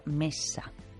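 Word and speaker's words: Messa: en el que nuestra invitada es Alba Mesa. Se dice así el apellido porque es con dos S's.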